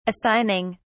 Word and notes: assigning: British English

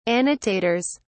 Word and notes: annotators: British English

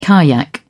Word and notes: kayak: British English

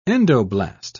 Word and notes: endoblast: British English

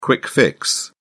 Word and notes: quick fix: British English

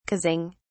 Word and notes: causing: British English

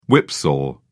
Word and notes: whipsaw: British English

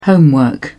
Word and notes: homework: British English

